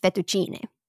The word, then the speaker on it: fettuccine: The plural in Italian of A is E, spelled E. It's pronounced basically A, fettuccine.